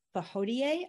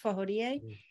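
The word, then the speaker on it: Fawohodie: a West African Adinkra symbol called the Fahodie, I think is how you pronounce it, Fahodie.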